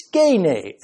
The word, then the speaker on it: skene: In Greek, that word is skene, S-K-E-N-E, skene.